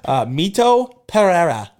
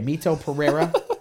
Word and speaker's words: Mito Pereira: Mito Pereira. – Okay, Mito Pereira.